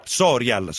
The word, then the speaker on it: Sorial: L'accento l'ho messo giusto sul cognome? – È Sorial. – Sorial, ok, quindi l'avevo messo sbagliato.